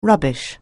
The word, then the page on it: rubbish: anglais